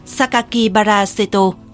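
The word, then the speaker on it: Sakakibara Seto (酒鬼薔薇聖斗): Kẻ này ký tên dưới thư bằng 6 chữ được phát âm là Sakakibara Seto, có nghĩa lần lượt là rượu, quỷ, hoa hồng, thánh, chiến đấu.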